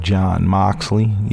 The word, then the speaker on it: Jon Moxley: All right, and then you also have the debut of Jon Moxley.